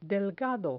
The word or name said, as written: delgado